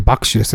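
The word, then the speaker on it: 麦酒: うん、麦の酒と書いて爆酒ですね。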